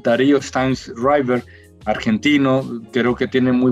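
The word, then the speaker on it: Sztajnszrajber: Tiene un apellido escrito muy extraño, con Z y J y demás, pero se pronuncia Steinreiber, pero se escribe muy raro.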